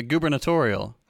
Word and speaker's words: gubernatorial: Anyways, in the gubernational election, which, by the way, yes, that is a word in English that I fucking hate, the gubernational. It sounds like you're intentionally mispronouncing. – Oh, the gubernatorial.